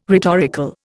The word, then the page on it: rhetorical: Indian